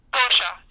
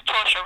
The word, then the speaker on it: Torchia: Yeah, it's actually pronounced Torsha, but yeah. – Torsha. I'm sorry about that. – You know what? That's okay. It's hard to pronounce my last name. I understand that. – Okay, then. So it's Torsha, right? – It's Torsha.